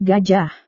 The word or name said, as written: gajah